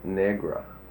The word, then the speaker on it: Negra: saying Negra. – Mm-hmm. – And he sits there and he tells me it's the old South pronunciation for Negro.